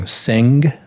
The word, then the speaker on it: Senge: The Fifth Discipline by Peter, I think I'm going to say it wrong, Senge? – I think you got it.